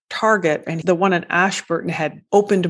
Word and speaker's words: Ashburton: Ashburton.